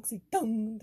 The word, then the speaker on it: OxyContin: Like, I guess you are still making that N sound. – Yeah. – Like, OxyContin.